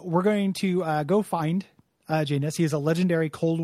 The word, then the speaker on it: Janus: That's how they pronounce it. It's not Janus. You know, it's not like the Greek god. It's Janus, like anus, is how they say it.